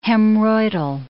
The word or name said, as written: hemorrhoidal